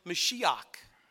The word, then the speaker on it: Mashiach: It's pronounced Mashiach. In English, it's Messiah.